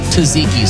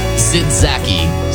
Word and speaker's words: tzatziki: Not only can Ella Langley sing on KJ97, she can also teach Theo Von how to pronounce the word tzatziki sauce. No, it is not zitzaki sauce. It's